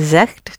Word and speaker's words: zegt: Ah, but I didn't hear the T for je. – Je zegt het zo, hè? – Zegt. – Zegt. – You made it more of a K rather than a G.